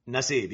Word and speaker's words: Nassib: So this is a clip from that show, and it's Carl Nassib. Is I saying that right? – Nassib, yes.